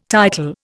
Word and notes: title: Indian

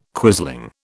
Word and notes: quisling: British English

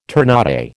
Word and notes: ternate: American English

